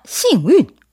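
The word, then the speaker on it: 싱운 (幸运): 네, 발음 비슷해요. 이해가 아니고 2번 싱운.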